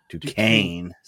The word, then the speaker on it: Duquesne: So DuCoin, how do you say it? – Duquesne.